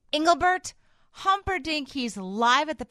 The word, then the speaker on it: Engelbert: Engelbert Humperdinck. – No, but you didn't have to say it correctly. It's Engelbert. – Oh, Engelbert. – Not Engelbert.